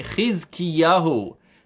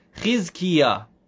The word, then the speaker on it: Chizkiyahu: His name in English, he's known as Hezekiah, but we're going to pronounce it the Jewish way, Chizkiyahu. Chizkiyah, we'll say in short. Chizkiyah. Chizkiyah, like challah, chuppah.